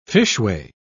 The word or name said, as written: fishway